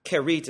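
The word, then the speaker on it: Kerith: By the way, the location of this brook, Kerit is how it's pronounced in Hebrew.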